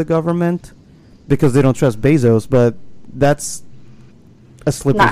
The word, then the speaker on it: Bezos: That's how you say his name? Jeff Bezos? – Yeah.